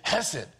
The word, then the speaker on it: hesed: I have to say it that way. I'm trying not to get any phlegm on the front row, right? You have to say, because that's how it's pronounced. It's got a little bit of oomph in it. It's hesed.